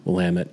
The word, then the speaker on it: Willamette: Willamette is rhymes with dammit, rhymes with Janet. So think Rocky Horror.